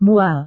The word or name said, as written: mual